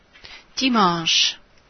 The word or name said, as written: dimanche